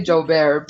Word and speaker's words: Joubert: Is it Joubert? – I say Joubert. A lot of people say it because it's a French Yeah, I was like, it looks French, so I wanted to.